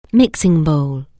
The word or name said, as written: mixing bowl